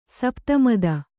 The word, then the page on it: ಸಪ್ತಮದ: sapta mada; Kannada